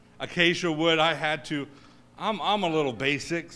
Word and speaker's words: Acacia: And I did not want to get up here and say acai wood.